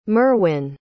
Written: merwyn

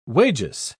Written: wages

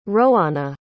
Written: roana

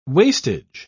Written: wastage

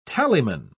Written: tallyman

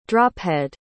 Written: drophead

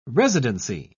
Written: residency

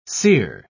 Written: sear

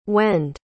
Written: when'd

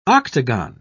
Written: octagon

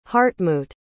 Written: hartmut

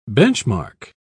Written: benchmark

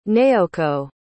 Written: nayoko

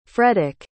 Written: fredek

